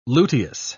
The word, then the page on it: luteous: British English